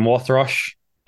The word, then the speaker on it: Wothrosch: And I'm joined today by Phil from Wothrosch.